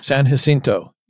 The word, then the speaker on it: San Jacinto: He acknowledges that he is working on trying to find the doubloons from the San Jacinto. I know I'm butchering that name. – San Jacinto? Jacinto? – San Jacinto.